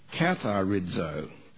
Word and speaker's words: katharizo: The word prune, I'll spell it K-A-T-H-A-I-R-O, kathairo, K-A-T-H-A-I-R-O, that's the Greek word, I prune. The word I make clean is katharizo, K-A-T-H-A-R-I-Z-O, katharizo.